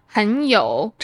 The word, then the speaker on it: 很: 我赢了比赛后我感觉很有成就感。这里这个很 is pronounced a second tone because of the tone sandy.